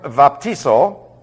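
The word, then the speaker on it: vaptizo (βαπτίζω): Point five, the word vaptizo, that's B-A-P-T-I-Z-O. Now, I pronounce the vita with a V sound because that's the way it is in modern Greek.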